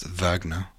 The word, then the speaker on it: Wagner: Acoustic Heel says, quick refresher on Wagner, pronounced Wagner,